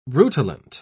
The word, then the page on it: rutilant: British English